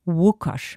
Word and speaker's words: Łukasz: L mit dem Strich wird nicht wie ein L ausgesprochen, auch nicht wie ein L, sondern wie ein englisches WH, Łukasz.